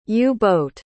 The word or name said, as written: u-boat